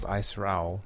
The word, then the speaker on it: Ayesirowl: I have a band called Aisrowl. It's spelled AYESIROWL.